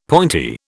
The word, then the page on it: pointy: British English